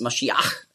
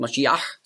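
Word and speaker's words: Mashiach (משיח): Und Messias, das ist einfach die Aussprache, die Griechen konnten nicht Mashiach sagen. Weil es gibt ja kein Sch-Laut im Griechischen. Darum sagen auch die modernen Griechen, die sagen ja nicht Fisch, sondern Fis. Also sie haben schon gelernt. Also darum wurde aus Masch, wurde Mess. Und dann Iach mit dem Het am Schluss. Mashiach hat man früher ausgesprochen. Mashiach. Da hatten sie auch Mühe.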